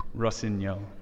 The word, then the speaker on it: Rossignol: And then looking at the women's, we had Colleen versus, excuse me for the pronunciation, but Julie Rossignol. Is that right? – Rossignol. – Thank you. Your French is way better than mine.